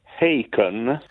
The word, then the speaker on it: Haken: Allora, il gruppo si chiama Haken. – Haken. – È scritto Haken, mm-hmm. ma loro stessi spiegano che si pronuncia come Bacon. perché evidentemente anche in Inghilterra avranno dubbi sulla pronuncia.